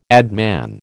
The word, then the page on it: adman: American English